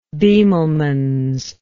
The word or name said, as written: bemelmans